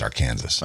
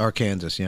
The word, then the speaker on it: Arkansas: It should be pronounced Arkansas. – Arkansas, yeah.